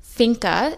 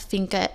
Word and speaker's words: fika: It's finka. It's spelled F-I-K-A, and the definition of finka is a moment to slow down and appreciate the good things in your life.